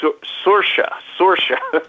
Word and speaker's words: Saoirse: It's pronounced Saoirse, but it's spelled S-A-O-I-R-S-E, which is like very confusing.